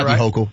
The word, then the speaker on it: Hochul: But Hochul, how do you pronounce that? – Hochul. Yeah.